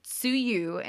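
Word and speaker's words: tsuyu (梅雨): The rainy season, or tsuyu in Japanese, which can also be pronounced bayu, literally means plum rain.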